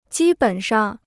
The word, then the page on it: 基本上: jī běn shang